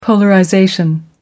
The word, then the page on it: polarization: American English